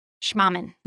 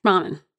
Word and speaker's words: Schmahmann: S-C-H-M-A-H-M-A-N-N. Schmaman. – Schmaman. – Schmaman.